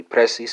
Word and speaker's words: presses: Second person singular, Portuguese speakers, you know that's personas, spelled P-R-E-S-S-I-O-N-A-S. Italian speakers translate the Portuguese to the Italian. That's going to be pressi, spelled P-R-E-S-S-I. French speakers translate the Italian to the French. It's going to be press, spelled P-R-E-S-S-E-S. S is silent. German speakers translate the French to the German. It's going to be draukst, spelled D-R-U-C-K-S-T.